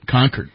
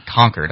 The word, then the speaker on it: Concord: Concord, that's right. – Which we don't say properly. We say Concord up there. – Yeah. – It's spelled like Concord.